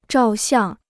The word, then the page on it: 照相: zhào xiàng